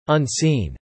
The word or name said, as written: unseen